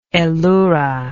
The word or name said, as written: elura